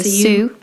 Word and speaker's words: Sioux: I would have said Sioux, so I'm glad. – The X isn't pronounced. – So Sioux tribe.